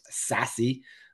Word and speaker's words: SASE: What is the difference between SASE, pronounced sassy, which that's not how it's spelled? – I love how you say it, though.